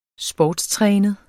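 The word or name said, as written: SPORTSTRÆNET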